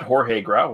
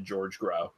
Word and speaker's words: Jorge Grau: I got Jorge Grau right. – You did, yes. – But I researched that one. Yeah. I looked up the pronunciation because otherwise I just would have said George Grau.